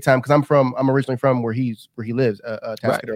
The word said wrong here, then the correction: Atascadero: I say that wrong every time. Yeah, Atascadero, which I lived 20 minutes down the road, down the highway from him.